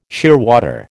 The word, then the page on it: shearwater: American English